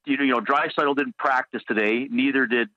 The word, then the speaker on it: Draisaitl: But Leon, and I hope I'm pronouncing his last name, Draisaitl, is that how you correctly pronounce it? – Draisaitl was actually second in the league to Auston Matthews in goals.